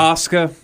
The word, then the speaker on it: Asuka: Asuka, but yeah.